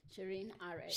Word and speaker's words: Shirin Ariff: Shirin Ariff.